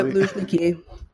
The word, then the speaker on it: Luzhniki: Is this Luzhniki? Am I pronouncing that terribly? – Yeah, Luzhniki. Mm-hmm.